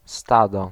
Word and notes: groupe: [ɡʁup]; France

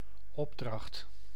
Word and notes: marché: /maʁ.ʃe/; /maχ.ʃe/

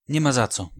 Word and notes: nie ma za co: /ɲɛ ma z̪a t͡s̪ɔ/

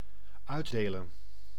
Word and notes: dispense: IPA : /dɪsˈpɛns/

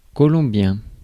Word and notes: colombien: [kɔ.lɔ̃.bjɛ̃]; France

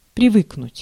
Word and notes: привыкнуть: /prʲɪˈvɨknʊtʲ/

